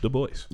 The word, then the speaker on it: Du Bois: Also, fun fact I learned recently, because I was calling him Du Bois, that it was actually pronounced Du Bois. – Yeah. – Du Bois. Like, Du Bois, like, on purpose.